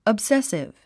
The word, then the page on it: obsessive: əbsésiv; オブセッスィブ